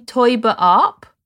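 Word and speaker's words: Taeuber-Arp: Sophie Teuber-Arp. – Exactly right. Yeah. – Yeah. – I include that on the first page so that you know how to pronounce it. So yeah, it's like toy.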